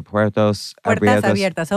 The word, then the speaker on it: Puertas Abiertas: It's, I don't know how to say it, Puertas Abiertas, Open Doors.